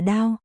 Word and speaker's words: đao: Tặc, đồ không có văn hóa, chữ này đọc là đao, nhất thời Phật tại đao lợi thiên, vị mẫu thuyết pháp.